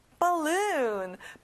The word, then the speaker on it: balloon: 끝에 U 소리를 길게 해볼게요. Balloon.